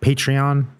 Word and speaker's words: Patreon: He's not a fuck-faced peasant anymore because he pledged on our patron. – You don't say. Is that how you say it? Patreon? – Indeed.